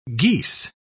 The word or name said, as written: geese